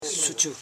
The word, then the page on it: suchu: su.chu; Koro